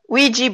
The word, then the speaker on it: Ouija: I don't know if I will pronounce correctly, but it's Ouya or Ouija. – Ouija board. Yes.